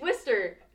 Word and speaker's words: Worcester: Okay, because Izzy is from, I'm gonna butcher it, Worcester? It's Worcester! – Yeah. – Yeah, I always want to say Worcester! – Because that's how it looks.